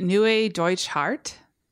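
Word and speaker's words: Neue Deutsche Härte: Neue Deutsche Harte.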